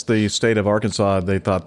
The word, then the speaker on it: Arkansas: But in Kansas, the river that is named for, most people call it Arkansas. What do you call the river there in Kansas? – You said it right. You said it right Arkansas. – The first time.